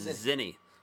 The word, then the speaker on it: Tzinny: It is T-Z-I-N-N-Y, but the T is silent. – So it's Zinny.